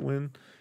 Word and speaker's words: Ng: Stuart NG is how you spell it. Wynn.